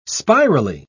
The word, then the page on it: spirally: British English